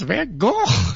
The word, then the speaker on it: Van Gogh: Like, my high school art teacher would always say, it's actually pronounced Van Gogh.